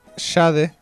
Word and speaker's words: Sade: Sí, vamos a escuchar a Jade, se pronuncia así. – Ah, ¿se pronuncia así? – Sí. – Pero se escribe... – Sade, como el Marqués de Sade.